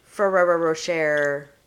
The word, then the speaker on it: Ferrero Rocher: We are eating Ferrero Rocheros. – Ferrero Rocher. – I know I don't say that right.